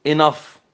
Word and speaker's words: enough: إذا كانت في آخر الكلام بقى حضراتكم إما أن لا تنطق though و although و through و أو لا أو تنطق فاء، enough tough rough laugh يعني يضحك cough يعني يسعل يكح وهكذا.